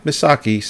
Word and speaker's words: Misaki: If you want to give 40 minutes for the backstory of Kafuku, I think the driver was it Misaki. – Misaki. – I feel like every single name I've mispronounced. – No, Misaki sounds right.